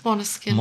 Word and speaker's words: Måneskin: Måneskin. – Måneskin-Hymne. – Du machst das A mit dem Ding, machst so ein bisschen wie ein O, aber nicht ganz ein O.